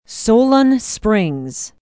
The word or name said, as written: solon springs